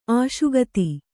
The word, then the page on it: ಅಶಕ್ತ: kannada